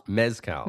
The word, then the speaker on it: mezcal: Muscal? – Mezcal. – Mezcal. See, I always say these things wrong.